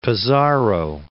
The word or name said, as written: Pizarro